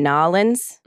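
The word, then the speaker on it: New Orleans: And then 2 years after that, in April of 2019, they got married in Nolens. – Mm. – That's how the locals say it.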